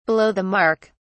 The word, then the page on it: below the mark: British English